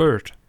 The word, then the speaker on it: Byrd: Christo Redentor von Donald Bird, Bright, Bird, B-Y-R-D. Ich weiß nicht, wie er ausgesprochen wird. Sorry an der Stelle. – Bird. Bird. Bird.